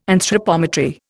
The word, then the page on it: anthropometry: Indian